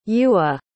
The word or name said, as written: Yua